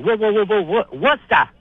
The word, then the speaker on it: Worcester: Because we're old school. Worcester. – Yeah, two syllables.